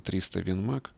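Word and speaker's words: 300 Win Mag: Многие читатели наверняка знают, что название 300 Винмаг, произносится 300 Винмаг, относится к пуле, которой снаряжается патрон 300 Винчестер Магнум 7,62 на 67 мм.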